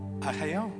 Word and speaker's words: Ageo: How do you say Haggai in Spanish? – Haga. Bueno, Haggai. – Ageo. – Ageo.